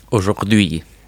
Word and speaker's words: aujourd'hui: Aujourd'hui.